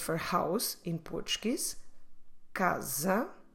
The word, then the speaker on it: casa: So every time you see S between vowels within a word, let's pronounce it like z. I do have here some examples. The first one, casa, casa.